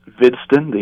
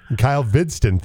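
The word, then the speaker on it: Hvidston: Kyle Havidstein, am I getting that right? – Vidston, H is silent. Vidston, thank you. – Like the Viking, yeah. – All right. Kyle Vidston, thank you.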